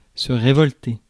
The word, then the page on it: rebel: /ˈɹɛbəl/; /rɪˈbɛl/